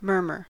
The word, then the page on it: murmur: American English